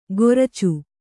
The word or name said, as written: ಕೊರ್ಚು